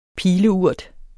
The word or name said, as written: pileurt